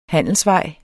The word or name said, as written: handelsvej